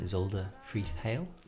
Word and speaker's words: Isolde Freeth-Hale: Welcome, Isolde Freeth-Hale.